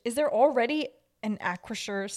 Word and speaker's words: Acrisure: And wait, there's already on the first syllable. Ack. – What the hell is that? – Acrisure. – They have the Acrisure Stadium. – The final syllable sounds like the word sure.